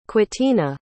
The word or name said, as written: quitina